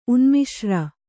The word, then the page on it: ಉನ್ಮಿಶ್ರ: Kannada